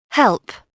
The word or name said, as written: help